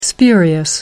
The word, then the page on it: spurious: American English